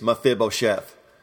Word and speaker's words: Mephibosheth: The correct pronunciation, according to my wife Sharon, and to those who know how to speak English, is Mephibosheth.